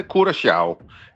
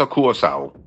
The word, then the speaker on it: Curaçao: Ja, hvis du må tage det helt korrekt, så siger det, hedder Curaçao. Det der C med den lille krølle under, det får sådan en sjav-lyd, men langt de fleste, de udtaler blot som et SC, så Curaçao.